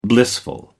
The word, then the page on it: blissful: American English